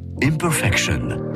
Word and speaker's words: Imperfection: Mais malheureusement, elle ne chante pas en français, donc il faut le prononcer Imperfection. – Eh bien bonjour, c'est Céline Dion. – Découvrez sur France Bleu Imperfection, extrait de Courage, le prochain album de Céline Dion.